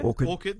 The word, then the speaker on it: orchid: Yeah, Lois on Facebook wanted to share a photo of her pink rock orchard. It does so well every year. – Orchid, excuse me.